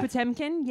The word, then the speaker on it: Potemkin: Potemkin, yeah. – Thank you. 1925. And then Brian De Palma, The Untouchables. – Yeah. Mm-hmm. – Shout out to Brian De Palma fans. – Battleship Potemkin was a film that we had to watch in film school.